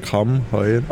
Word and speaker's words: Kammheuen: Also ich muss ganz leicht berichtigen, es ist Kamm heuer.